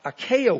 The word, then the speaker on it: acacia: It was bronze, and it was made of acacia wood. I hope I'm pronouncing that right. I don't think I am. There's two C's in it. Acacia. Thank you.